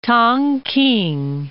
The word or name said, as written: Tongking